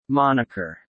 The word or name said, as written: moniker